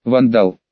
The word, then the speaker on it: Вандал: «Можно просто по имени, Вандал, ударение на первый слог.